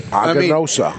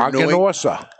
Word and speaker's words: Aganorsa: Aganosa. – I mean, it's completely phonetic, but it seems confusing for Aganosa. Aganosa. – Yeah. – But I try to skip the R.